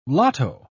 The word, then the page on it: lotto: British English